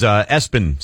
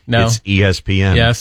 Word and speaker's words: ESPN: Do you say it Espen? And yeah. – He frequently has to say, no, it's ESPN.